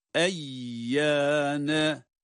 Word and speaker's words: Eyyâne: Eyyâne. – E sesi bakınız. Eyyâne.